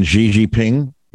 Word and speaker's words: Xi Jinping: Chairman Xi Jinping. – Gigi? Yeah, you got it. Yeah. – Gigi. It sounds like Gigi, but OK.